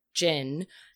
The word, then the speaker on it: cin: It is spelled C-I-N, but pronounced like jinn, which basically people would equate to a genie at times.